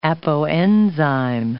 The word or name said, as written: apoenzyme